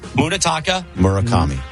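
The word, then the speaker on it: Munetaka Murakami: Munetaka. Murakami.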